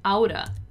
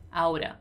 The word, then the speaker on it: ought to: So ought to in an everyday speech, in a fast speech, normally is going to be pronounced as outa.